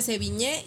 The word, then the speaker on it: Sevigne: Luego es difícil eh, encontrar en redes sociales algo, pero se escribe Sevigne, se pronuncia Seviñé, y bueno, eh, el logotipo es creo que así, ¿verdad? – Sí, ya es S-E-V-I-G-N-E.